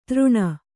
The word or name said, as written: ತೃಣ